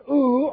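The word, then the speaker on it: ou (οὐ): Me, or M-E, with long E, which we have, and ou, O-U, we don't have that, but these are the two negatives.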